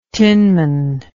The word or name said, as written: tinman